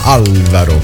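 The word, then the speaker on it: Álvaro: Sta per Alvaro De Luna su Alvaro De Luna, anzi l'accento sulla prima A, Alvaro.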